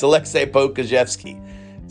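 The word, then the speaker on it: Aleksej Pokuševski: Don't forget the silent J at the end of Alexei. It's Alexei Pokusevski.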